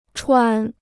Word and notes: 传: chuán

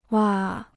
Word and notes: 哇: wa

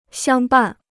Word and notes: 相伴: xiāng bàn

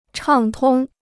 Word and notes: 畅通: chàng tōng

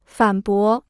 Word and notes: 反驳: fǎn bó

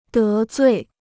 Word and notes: 得罪: dé zui

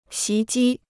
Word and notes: 袭击: xí jī

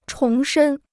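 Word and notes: 重组: chóng zǔ

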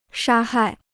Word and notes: 杀害: shā hài

